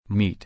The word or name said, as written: mete